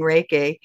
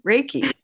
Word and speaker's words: Reiki: So now I'm just like, oh, it rhymes with Jakey, Reiki.